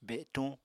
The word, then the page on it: betuñ: Apatani